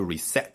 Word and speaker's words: reset: 啊 ,reset 做动词，它发音稍微有点不太一样，就 reset.